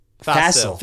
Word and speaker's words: facile: F-A-C-I-L-E. – Yeah. – Fassil.